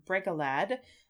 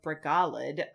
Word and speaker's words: Bregalad: This is a song from The Two Towers that Quickbeam, also called Bregalad, or Bregalad, I guess, if we're following Elvish pronunciation rules of second, you know, to last syllable emphasis.